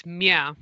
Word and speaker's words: Mya: It's meow. – Yeah. – It's like saying yeah with an M on it, so meow.